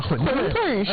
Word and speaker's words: 馄饨: 馄饨是正确读音，不是炖。